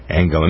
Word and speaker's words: Angela: They pronounce the G hard. Angela.